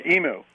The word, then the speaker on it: imu: Is it pronounced emu? I-M-U. – Yeah. – Imu? – Yeah, emu.